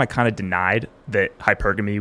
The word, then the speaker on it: hypergamy: Do you say hypergamy or hypergamy? – The word is hypergamy. – Yeah. – If you pronounce it from the Greek, it's hypergamy.